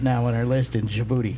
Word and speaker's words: Djibouti: Djibouti. – Djibouti? – Yes. – Who's booty? – It's spelled with a D, but it's pronounced Djibouti.